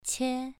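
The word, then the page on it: qie: tone 2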